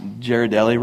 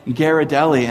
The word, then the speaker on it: Ghirardelli: Someone came up to me after last service and said, "Do you know that you pronounced Ghirardelli wrong?" I was like, "What?" And they were like, "It's Ghirardelli." And then someone was like, "No, it's Ghirardelli."